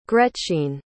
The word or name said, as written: gretchine